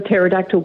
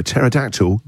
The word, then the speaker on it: pterodactyl: Why can't you hear a pterodactyl go to the toilet? – I don't know. Why can't you hear a pterodactyl go to the toilet? – Because the P is silent.